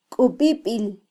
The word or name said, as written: kubibil